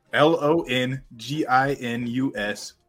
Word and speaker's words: Longinus: Are you pronouncing it correct? – Yes. Spell it. L O N G I N U S. Smasher, Longinus Smasher.